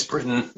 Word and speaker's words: Britton: It's Britton, but with a strange name, you answer to anything.